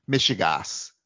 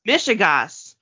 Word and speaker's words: Mishigas: Mishigas. – Mishigas. Like Michigan.